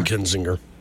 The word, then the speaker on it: Kinzinger: Yeah, Adam Kinzinger.